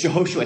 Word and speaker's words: Jehoshua: And, and that's, and you guys, some of you guys don't know me, but my legal name is Jehoshua. It's spelled J-E-H-O-S-H-U-A.